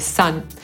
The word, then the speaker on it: son: Podsumowując, po angielsku słowa syn i słońce to homofony, czyli wymawia się je identycznie pomimo różnej pisowni. Słońce to the sun, a mój syn to my son.